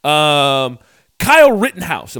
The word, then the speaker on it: Kyle Rittenhouse: Kyle Rittenhouse. Am I saying his name right? Did I get it? – You got it.